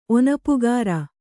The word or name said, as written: ಒನಪುಗಾರ